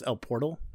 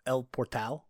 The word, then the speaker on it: El Portal: That's not pronounced El Portal. That's El Portal.